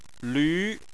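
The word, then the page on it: lv: tone 2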